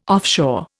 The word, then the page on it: offshore: Indian